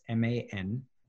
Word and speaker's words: Mansharamani: It's MANSHARAMANI.